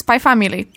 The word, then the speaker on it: Spy Family: No więc ja opowiem wam o mandze i anime, które nazywa się Spy Family. No pisze się Spikes Family, ale właśnie dzisiaj się dowiedziałam, że czyta się to jednak Spy Family.